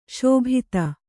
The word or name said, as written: ಶಾಬ್ದ